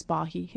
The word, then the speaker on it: Bahi: And my name in English is Elizabeth Bahi. So the correct pronunciation is Bahi in terms of the last name.